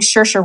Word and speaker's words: Saoirse: I think Saoirse Ronan. I hope I pronounced her name right. She's really amazing. Saoirse.